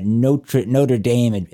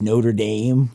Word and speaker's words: Notre Dame: It's from the University of Notre Dame of Maryland. I always want to say Notre Dame because that's how it should be pronounced, right? But over here, I guess it's Notre Dame, like it is, like the main Notre Dame of Maryland, though, which is the last women's college in Maryland, right? – Yeah.